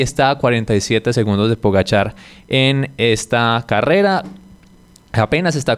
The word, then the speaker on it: Pogačar: O Pogačar también es la pronunciación correcta.